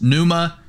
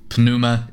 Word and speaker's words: pneuma: And so that moves us finally to the word spirit, which is, depending on how you believe Greek was originally pronounced, pneuma or pneuma, whether or not you think the P's should be pronounced or not. – Sure. – I think it should. I'm from the school of pneuma is how I would pronounce spirit.